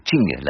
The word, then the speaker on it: Qing: Donc vous dites Qing, hein, pour Qing, c'est Qing, la prononciation chinoise, à laquelle on n'est pas contraint d'ailleurs.